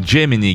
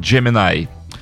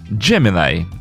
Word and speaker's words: Gemini: Я все думал, Джемини, Гемини. Нет, правильно произносить. Ну, правильно же, как Бьорн произносит. Так вот, Бьорн произносит Джеминай. Джеминай.